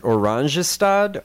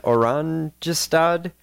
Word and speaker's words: Oranjestad: Is it Oranjestad? Oranjestad? – Oranjestad.